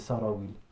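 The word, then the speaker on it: sarawil (سراويل): on a inventé le mot sirwel, mais en fait, à la base, le mot sarawil, c'est sarawil. Ça se dit comme ça, il n'y a pas de singulier.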